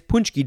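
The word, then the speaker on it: pączki: It is Punchki Day. It is spelled P-A-C-Z-K-I.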